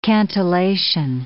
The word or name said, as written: cantillation